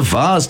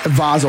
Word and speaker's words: vase: It's a vase today. – It is. – Well done. – It is a vase. – Thank you. – Vase or vase is the pronunciation.